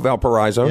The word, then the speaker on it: Valparaiso: They drop a 72-65 a road loss this weekend to Valparaiso. Is that how you say it? Valpo, Valparaiso.